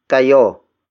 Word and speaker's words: kayo: The next word is kayo. Kayo. Kayo. Kayo is spelled as K-A-Y-O.